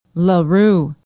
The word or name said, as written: la rue